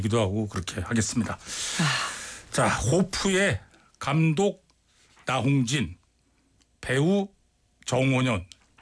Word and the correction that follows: 정호연: 발음 잘 안 됐어요. 정호연 이렇게 두 분과 함께하도록 하겠습니다.